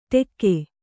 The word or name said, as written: ಟೆಕ್ಕೆ